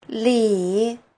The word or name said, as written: li3